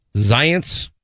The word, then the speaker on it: Zients: It's Zients. – Zients? – Yeah, like science.